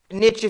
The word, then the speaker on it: niche: Niche if you're American, niche if you're English.